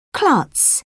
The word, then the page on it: klutz: British English